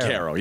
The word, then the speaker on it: Banchero: Banchero, yeah.